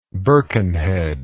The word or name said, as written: hms